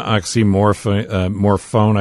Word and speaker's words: oxymorphone: Hydrocodone, oxymorphone, I believe is the pronunciation. – Yep.